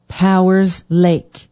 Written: powers lake

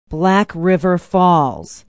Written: black river falls

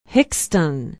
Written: hixton

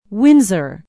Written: windsor

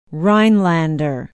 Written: rhinelander

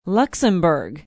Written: luxemburg

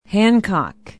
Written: hancock

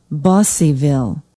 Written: busseyville

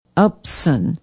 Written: upson

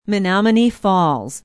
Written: menomonee falls

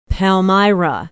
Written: palmyra

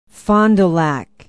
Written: fond du lac

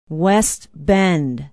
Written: west bend

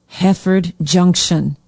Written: heafford junction

